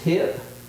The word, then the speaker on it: Hiep: I want to introduce you to a gentleman whose name is Hip, just like your hip, spelled H-I-E-P, Ma, M-A.